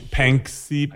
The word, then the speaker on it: Panksepp: So these researchers at Washington State, this guy, Panskeep, is that how it's pronounced? Panksepp.